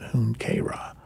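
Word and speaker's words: Junkera: That's not spelled like that at all, but that's how they say you pronounce it, Hunkera.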